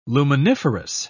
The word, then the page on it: luminiferous: British English